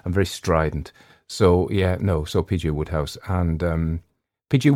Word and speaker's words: Wodehouse: Somebody tried to correct my pronunciation and say, no. It's written Wodehouse, and they thought it should be pronounced Wodehouse, but it shouldn't.